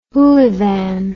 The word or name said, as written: hoolivan